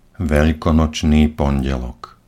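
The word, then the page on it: velkonocny pondelok: Slovak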